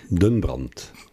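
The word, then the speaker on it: Den Brandt: It's in the center of Den Brunt Park, if I said that correctly. Am I close? – Den Brunt.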